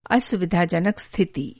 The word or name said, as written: असुविधाजनक